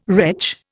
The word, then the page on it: retch: Indian